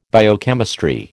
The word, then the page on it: biochemistry: American English